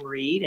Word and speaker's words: Reid: I would just suggest you just type in Flow and Pam Reed, and my last name is spelled R-E-I-D, and it'll come up.